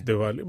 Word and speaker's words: Diwali: Am I saying it right? There's a V sound on, on the W, is it? Yeah, yeah it's yeah. Diwali, but that's fine.